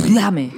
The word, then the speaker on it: Dhame: Es de D-H-A-M-E, Dame. – ¿No será Dafne? – No, Dafne es con una... – Pronúncelo como sería en inglés. Dame.